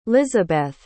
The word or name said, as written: lizabeth